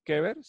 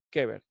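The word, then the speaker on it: kever (קבר): Aquí donde dice sepulcros, es esta palabra que nosotros más o menos, déjenme ver, ahí está, que nosotros suena así, kever. Así se escribe, kever, se lee de aquí para allá en hebreo, kever.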